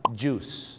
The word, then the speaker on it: juice: juice นะครับผม juice ไม่ได้ออกเสียงว่าจุ้ยนะฮะ juice นะครับผมท้องเสีย juice เลยนะครับผมน้ำผลไม้ไม่ใช่จุ้ยนะฮะต้องออกเสียงว่า juice